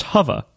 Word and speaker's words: bokeh: And some of that is because I really want that bokeh, whatever. – It's pronounced hova.